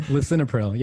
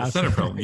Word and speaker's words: lisinopril: And I mispronounce how do you pronounce it? Lisoprolol? – Yeah, lisinopril. Yeah, lisinopril. Exactly. If